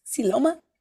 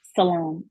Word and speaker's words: Salome: Wait, spell it one more time for me. – SILOAM. – Siloma? – Salome. It's still Salome.